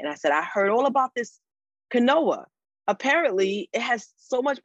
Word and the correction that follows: quinoa: And he was like, babes, I love you, but it's pronounced quinoa.